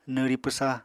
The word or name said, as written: nwri pwsa